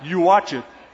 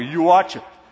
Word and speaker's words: Uatchit: So if it's interesting, like, Uatchit, like a New Yorker, Uatchit. U-A-T-C-H-I-T, if you were to spell it, Uatchit.